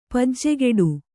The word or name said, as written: ಪೂಜೆಗಟ್ಟು